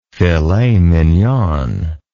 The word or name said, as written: filet mignon